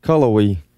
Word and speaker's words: Cullowhee: Culloway. – C-U-L-L-O-W-H-E-E.